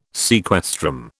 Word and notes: sequestrum: British English

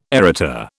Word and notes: Arita: British English

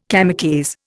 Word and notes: kamikaze: Indian

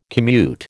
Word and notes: commute: American English

